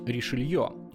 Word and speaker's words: Ришелье: Но даже это не совсем правильное произношение. По-французски нужно говорить Ришелье. А так уж получилось, что первый раз перевели неправильно, и с тех пор Ришелье превратился в Ришелье.